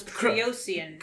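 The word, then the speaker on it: Kriosian: Because I always think it sounded like someone who was from Croatia. – Oh, no, it was Creosian.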